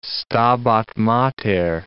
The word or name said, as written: Stabat Mater